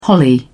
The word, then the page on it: holly: British English